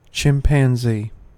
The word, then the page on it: chimpanzee: American English